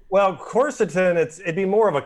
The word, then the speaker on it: Quercetin: I'm going to butcher the name of it, but Quercetin? Is that it? How you say it? – Quercetin. – Quercetin.